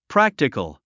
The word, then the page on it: practical: prǽktikəl; プラクティカル